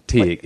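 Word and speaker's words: Teague: Tig. It's spelled, it's T-E-A-G-U-E pronounced. – Oh, like know, some Tig. Sort of weird it's Irish. – Like pig. – Like crazy Tig.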